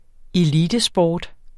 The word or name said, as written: elitesport